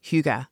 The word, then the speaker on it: hygge: They burn candles, they eat good food, they invite people into their cozy homes, and they embrace Hygge. That's the right pronunciation.